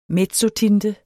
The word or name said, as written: mezzotinte